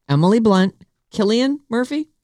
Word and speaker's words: Cillian: It is Cillian. – With a K. – With a K. – Okay. Even though it's spelled with a C.